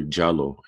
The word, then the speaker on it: Diallo: or sometimes it's pronounced Diallo, it's really Diallo in the Fulani language, where the NYPD shot at that Muslim brother who was innocent.